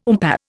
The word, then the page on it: oompah: Indian